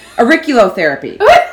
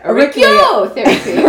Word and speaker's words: auriculotherapy: Auriculotherapy. Yeah, let's go with that one. – Try enunciating it on the fourth syllable. – Yeah. – Auriculotherapy.